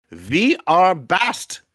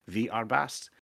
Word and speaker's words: Vi Är Bäst: It is a 2013's We Are the Best, or in Swedish, because it's a Swedish film, Vi Är Bäst, according to Google Translate. That's how you say it. Vi Är Bäst.